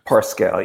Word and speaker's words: Pascal: Pascal, yeah.